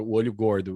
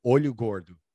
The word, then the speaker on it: olho gordo: They call it olho gordo.